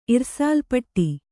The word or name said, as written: ಇರ್ಸಾಲ್‍ಪಟ್ಟಿ